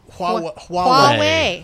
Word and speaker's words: Huawei: I can't pronounce this, the Huawei? – Huawei. – I can't do it. – H-U-A-W-E-I. – Anyway, so H-U-A-W-E-I, they introduced the world's first Honeycomb 3.2 OS running tablet.